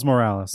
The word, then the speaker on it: Morales: Miles Morales.